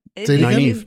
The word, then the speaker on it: Nynaeve: I think I like, you know, I always like seeing, like, I think Nynaeve, I'm never going to get this right. – Nynaeve, yeah. – Nynaeve, okay, sorry, I'm looking at the name and pronouncing it.